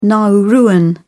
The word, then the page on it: nauruan: British English